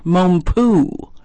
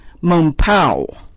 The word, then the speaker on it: Mompou: I thought he was Frédéric Mompou, but he's very Spanish. Well, his mother was French, but his name is pronounced Frédéric Mompou.